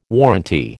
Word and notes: warranty: American English

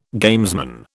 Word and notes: gamesman: British English

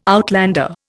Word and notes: outlander: Indian

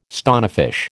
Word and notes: stonefish: American English

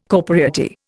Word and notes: corporeity: Indian

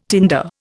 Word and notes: tinder: Indian

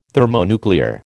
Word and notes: thermonuclear: American English

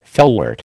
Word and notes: felwort: American English

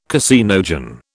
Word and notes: caseinogen: British English

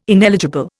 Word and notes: ineligible: Indian

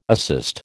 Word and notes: assist: American English

